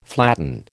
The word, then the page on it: flatten: American English